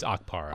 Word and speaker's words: Okpara: One thing that happens a lot in college basketball is a big guy like, and the key guy for Tennessee, Okpara, is that how you say it? Okpara or Okpara? – I believe it's Okpara.